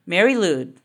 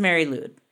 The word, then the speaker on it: Mari Lwyd: Mari Lwyd. – Okay. – It is Mari Lwyd. From what I know about Welsh language, every letter is pronounced, but they might not make the sounds you're expecting.